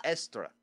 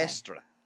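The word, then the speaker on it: Eostre: which was derived from a Germanic spring goddess named Itara. – Okay. No, I like the name. – No, I'm sorry. Estra. Okay. Estra. I'm sorry. I mispronounced that.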